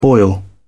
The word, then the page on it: boil over: British English